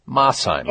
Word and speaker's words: Mosheim: All right, next up, we say hello in Mosheim, Tennessee. I hope I'm pronouncing that correctly. Hello. – Hello, Mosheim. – Mosheim. Okay, thank you.